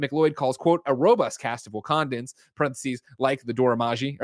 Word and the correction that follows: Dora Milaje: Dora Milaje.